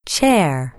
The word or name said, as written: chair